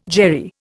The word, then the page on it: Jerry: Indian